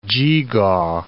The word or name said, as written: geegaw